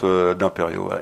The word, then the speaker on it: D'Imperio: Et mon nom aussi, Eric D'Imperio. Eric, il y a une petite particule, ça s'écrit avec un C et un K. et un D apostrophe D'Imperio.